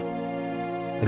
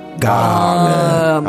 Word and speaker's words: Garmith: Garmith. You have to hit the R a little bit, though. – Garmith.